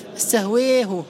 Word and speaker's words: استهوته: هو يقرأها بإمالة الألف، استهويه استهويه، وحتى تضبط المسألة كيف تنطق بحرف الأي في اللغة الإنجليزية، مثله تماما، هذه الإمالة الكبرى.